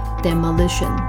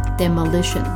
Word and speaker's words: demolition: demolish 转成名词的时候啊，那个重母音的发音要稍微改一下啊，名词变成 demolition。 demolition。